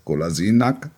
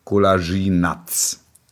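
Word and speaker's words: Kolašinac: Die Aussprache des Nachnamens des Spielers. Golasinak. Er ist jetzt nicht mehr Nack, sondern Natsch. Und jetzt, Achtung, dringende Mitteilung an alle Redaktionen. Spieler heißt jetzt Golasinatz.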